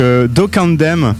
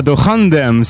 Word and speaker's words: Dokhandem: On a pu écouter, je vous ai fait une petite série un peu world music, on va dire, avec Dokandem, D-O-K-H-A-N-D-E-M. Vous pouvez prononcer ça Dokandem si vous êtes africain, que vous parlez un peu le Wolof. Sinon, c'est Dokandem, voilà.